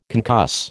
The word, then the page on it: concuss: American English